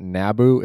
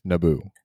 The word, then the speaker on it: Naboo: Alderaan, Exegol, Mustafar, Kijimi, Yavin 4, Naboo, Tatooine, and Jakku. – That was pretty close on most of those. It's not Naboo, it's Naboo, which is where I'm going on my date.